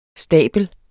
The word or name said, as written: stabel